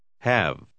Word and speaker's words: have: Acuérdese de pronunciar la segunda palabra como si no tuviera una E final. – We have to leave. – Have.